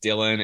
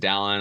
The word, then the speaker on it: Dailin: I've been called Dailin and Dallin. I'll respond to both.